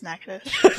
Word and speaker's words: Natchitoches: It's pronounced Natchitoches.